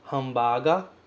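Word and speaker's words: hambaga (ハンバーガー): Essas opções são hambaga, que significa hambúrguer, e sanduíche, que significa sanduíche. Os dois vêm do inglês, adaptados para a pronúncia do japonês, certo? Hamburger, né? O hambúrguer virou aqui hambaga, e o sandwich virou sanduíche.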